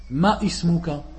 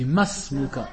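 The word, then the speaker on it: Masmuka (ما اسمك): Et de là tu entends souvent les gens qui font l'erreur et ils disent Ma Ismuka. On dit Masmuka. Le Alif, il est, on ne doit pas le prononcer. Et beaucoup d'arabophones font cette erreur. Ils disent Ma Ismuka, alors qu'on dit Masmuka.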